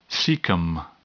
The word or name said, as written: cecum